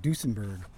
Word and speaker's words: Duesenberg: So now we've got to learn more about the Dusen. I always say it wrong. Duesenberg. The Duesenberg.